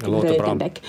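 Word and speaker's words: Ruitenbeek: Och samtidigt så har den här dokumentärfilmaren Stefan Ruitenbeck, jag vet inte om jag uttalar det här rätt, Ruitenbeck,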